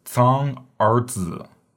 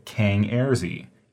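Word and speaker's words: cang er zi: And again, remember the C sound is pronounced with a TS. So this is zang arzi. Zang arzi. Please do not say Kang Erzi. If you say Kang Erzi, I will make fun of you.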